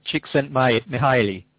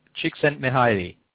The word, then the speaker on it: Csikszentmihalyi: Csikszentmihalyi. – Yes. That he's, say it again. – Csikszentmihalyi.